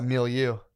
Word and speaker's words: milieu: I read beyond my fucking my milieu.